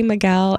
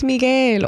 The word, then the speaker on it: Miguel: I know you guys say Miguel, and I know that that's how you say it in English, but it's Miguel, okay?